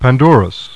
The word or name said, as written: PANDORUS